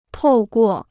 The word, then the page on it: 透过: tòu guò